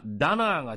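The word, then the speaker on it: Donald: ドナルドというのは英語発音ではダナーが近いようですが、もともとはゲール語のドムクナールから来た言葉で、世界の支配者という意味なんです。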